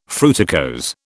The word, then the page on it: fruticose: British English